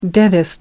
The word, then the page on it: dentist: American English